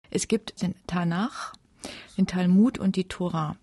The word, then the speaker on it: Tanach: Tanach ausgesprochen okay.